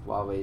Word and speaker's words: Huawei: saying that Rodney Hood's got something for your Hawaii Jack, or Huawei. Huawei is the right way to pronounce it, your Huawei Jack.